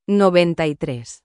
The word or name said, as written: 93